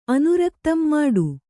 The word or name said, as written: ಮುಟ್ಟ